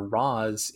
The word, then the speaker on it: Ra's al Ghul: Ra's al Ghul. – I agree totally. – And if we're basing it on an Arabic character, Ra's is the more traditional Arabic pronunciation from my experience.